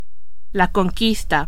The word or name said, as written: conquista